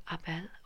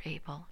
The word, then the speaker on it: Abel: And Abel, or Abel, depending on where you're from, how it's pronounced.